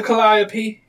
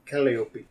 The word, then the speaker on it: Calliope: You go right through there, past the Calliope, and to the lost property. – Holy crap, I never would have been able to pronounce that. – Calliope.